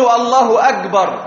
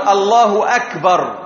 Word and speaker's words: الله أكبر: ومنهم من يقول الله أكبر فينطق الكاف كالجيم المصرية، يقول الله أكبر، وهذا خطأ، وإنما لابد أن يحقق الكاف، أن يقول الله أكبر، الله أكبر، وإلا فلا يجوز له أن يؤذن، لأن الأذان له آداب ينبغي أن نتعلمها كذلك.